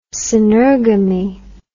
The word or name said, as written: synergamy